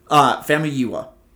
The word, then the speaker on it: Famuyiwa: Like, and honestly, okay, this episode is directed by, his name is Rick Famuyiwa? No idea. That's how you spell it. I double checked. Famuyiwa. – Yeah, that's how I would say it.